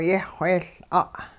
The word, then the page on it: yehwheeyL'a': hupa